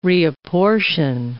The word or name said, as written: reapportion